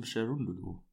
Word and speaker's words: Cherundolo: Äh, Steven Cherundolo. Oder wenn man den richtig so ausspricht. Korrekt. Ja, ich, ich hätte jetzt Cherundolo ausgesprochen als eher Deutscher, aber ja, der gute Steve.